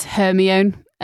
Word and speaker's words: Hermione: Rowling put an in-joke into the fourth book of Harry Potter because everyone who read the first three books were referring to Hermione as Hermione, so she gets Victor Crumb to pronounce it as Hermione so that she can put it right.